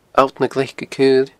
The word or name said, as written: alltnaglaicecaoile